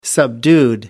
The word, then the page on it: subdued: American English